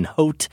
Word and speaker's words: haute: As someone majoring in French, I must side with Josh on this by saying that your accent barely registered, especially when you pronounce the H in haute. It is silent, but great effort nevertheless.